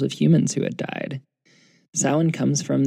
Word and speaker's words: Samhain: we have to know the history of its precursor, Samhain, that funny-looking word that looks like it should be pronounced Samhain. Samhain is a festival celebrated in Celtic paganism and Wicca.